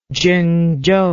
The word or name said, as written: zhengzhou